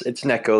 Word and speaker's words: Neko: Yeah, so it's Neko, like a Neko cat, but the token is pronounced Neko, and that is a sort of a naming convention for Near ecosystem, so Neko, which we're pretty excited about. Of course, a lot of people have made fun of us because they just think we're white guys mispronouncing Neko.